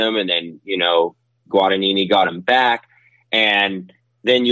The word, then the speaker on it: Guadagnini: I mean, you watched, and I don't want to say his name wrong, but Guadagnini, right? On the gas. – Yeah, right? – Yeah. Yes.